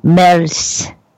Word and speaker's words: Merz: It's like M-E-R-Z. I've heard it pronounced the right way, and of course, that's escaping me today. – Merz. – There we go. – You can say it like Frederick. That. That's Merz.